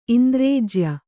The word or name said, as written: ಇಂದ್ರೇಜ್ಯ